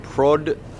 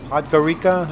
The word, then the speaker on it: Podgorica: Podgorica. – Podgorica? – Podgorica. I'm sorry. Yeah, I pronounced it wrong.